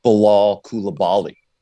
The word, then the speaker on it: Bilal Coulibaly: Do you want to try, Zach, or do you want me to try my debut pronunciation of Bilal Coulibaly? – I think you nailed it.